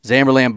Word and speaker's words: Zamberlan: Zamberlan boots.